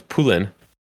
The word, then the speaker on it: Poulin: And then you have her last name's, I say Pauline, but it's French, so it's Poulin.